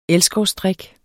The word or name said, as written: elskovsdrik